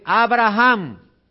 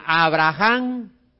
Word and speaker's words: Abraham: Ahora, oiga, esto, cuando usted menciona Abraham y usted menciona Abraham, es diferente la pronunciación. Porque cuando usted pone la H Abraham, usted está haciendo un énfasis y usted está colocando como un aliento ahí en ese nombre Abraham.